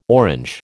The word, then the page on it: Orange: American English